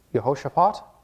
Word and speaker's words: Jehoshaphat: Just for some fun word study here, Jehoshaphat, that's how you say it, Jehovah Shaphat means God has judged.